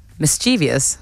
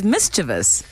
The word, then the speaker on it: mischievous: M-I-S. – Yeah. – C-H. – C-H. – Yeah. R-E. – Yeah. – V-O-U-S. – Mischievous. – Incorrect. – Is it mischievous? – Correct. – There we go. Mischievous, yeah. – Mischievous. It's incorrect pronunciation. Mischievous, three syllables. Mischievous, four syllables.